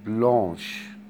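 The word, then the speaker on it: blanche: عاوز اقول مثلا مسطرة بيضاء، او عاوز اقول ترابيزة بيضاء اقول عليها بلونش، نفس الكلمة بس بزود لها اش، بلونش بلون بلونش يعني.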